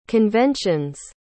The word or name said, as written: convention's